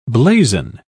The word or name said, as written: blazon